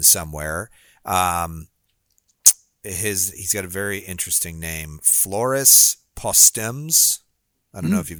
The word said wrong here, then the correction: Postmes: Postmes is how it's pronounced. You can go to his website, Floris Postmes, which is Postmes.